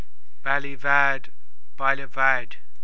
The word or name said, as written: Baile Bhaid